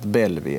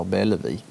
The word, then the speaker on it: Bellevue: Bellevue, fast uttalat på skånska, har alltså hetat Belvi eller Bellevi. eller Bellevue, ett rent läsuttal, upptecknat 1934 och det är då en gård också i Övesocken.